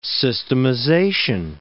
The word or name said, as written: systemization